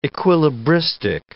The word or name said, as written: equilibristic